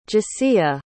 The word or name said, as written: Jessia